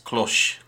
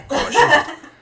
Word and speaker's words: cloche: It's a French word. It would be spelled pronounced cloche. – That's what I was going to say. – Yeah, cloche.